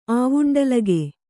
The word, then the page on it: ಆವುಂಡಲಗೆ: āvuṇḍalage